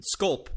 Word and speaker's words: Zandschulp: Sculp, by the way. I was pronouncing, just so you know. No, it's hard because at that point you're 7 syllables deep and you're like, just get through the name.